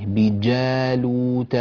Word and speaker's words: بجالوت: أخي الكريم أحمد جاحوح من المغرب، عطش الجيم أخي الكريم، يعني أعطي الجيم شدة في الصوت. معنى الشدة هي انحباس الصوت عند النطق بالحرف، انحباس ثم تنفجر، فتقول بجالوت وجنوده لا تقول بجالوت وجنوده، بجالوت وجنوده هكذا.